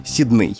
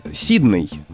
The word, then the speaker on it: Сидней: Это название австралийского города Сидней, которое, согласно словарям, и в том числе словарю трудностей для работников в СМИ Михаила Абрамовича Штудинера, произносится в образцовой речи именно так — Сидней. Но есть ряд людей, и это очень популярная тенденция, говорить «Сидней». Ну, потому что так говорят же в оригинале «Сидней», в английском говорят «Сидней».